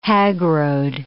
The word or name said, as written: hagrode